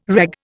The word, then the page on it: reg: Indian